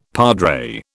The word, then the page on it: padre: British English